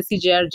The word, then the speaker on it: CGIAR: Is that how it's pronounced? CJR. Yeah, they have a gender platform.